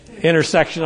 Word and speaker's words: intercession: That's the power of interception. How do you say it, everybody? – Intercession, all right.